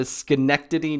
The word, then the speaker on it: Schenectady: So, Todd lives in the Boston area, and he traveled up to Schenectady, New York. Did I say it right? – You did. Good job.